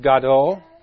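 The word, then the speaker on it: Gadol (גדול): This word is pronounced Gadol.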